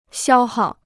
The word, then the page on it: 消耗: xiāo hào